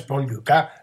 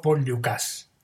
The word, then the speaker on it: Dukas: El amigo Edgardo me señaló que la pronunciación del nombre del compositor de El aprendiz de brujo no es Paul Dukas, sino Paul Dukas.